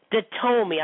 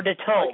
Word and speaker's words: Datome: His name is Luigi Datome. I believe that's how you pronounce his last name, or Datome.